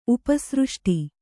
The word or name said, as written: ಉಪಸೃಷ್ಟಿ